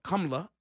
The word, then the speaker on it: Kamala: Now you're not paying attention to the person that's actually racist, to the point where Kamala Harris, or Kamala, I'll pronounce her name properly, her Indian name, she'll say months ago on the stage, Joe Biden, you're racist.